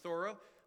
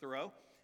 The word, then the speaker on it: Thoreau: Listen to what Henry David Thoreau said, and I did find out his name's pronounced Thoreau. I went through my whole life believing his name was pronounced Thoreau, and so for all you English majors or anything, it's Thoreau, not Thoreau, Thoreau, Thoreau.